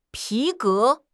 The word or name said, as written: 皮革